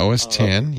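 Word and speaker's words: OS X: Yeah, OS 10, yeah.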